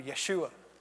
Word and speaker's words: Yeshua: You know the name Jesus, pronounced in Hebrew, Yeshua.